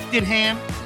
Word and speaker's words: Stidham: Didham. – I believe it's pronounced Stidham.